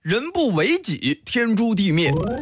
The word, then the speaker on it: 人不为己，天诛地灭: 等等，这句话的正确读音应该是人不为己，天诛地灭。